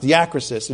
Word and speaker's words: diakrisis: So, but that's how it's pronounced, diakrisis, a judicial estimation.